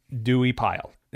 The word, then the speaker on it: Duiepyle: So the company was founded by a dude named Alexander Dewey Pile. And his name is spelled D-U-I-E-P-Y-L-E. – Dewey. – Dewey Pyle.